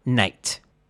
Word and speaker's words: knight: Basically, the first group would have looked at a word spelled K-N-I-G-H-T and said, "Knight," pronouncing each letter as a separate sound. And the second group would have known that the spelling was just a bit arbitrary. It was actually pronounced "night."